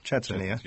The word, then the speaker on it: Chechnya: So if you are planning your trip to Moscow, Leningrad, or any area over there, who knows, Chechnya? Did I say that right? – Chechnya.